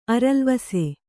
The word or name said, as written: ಅರಲ್ವಾಸು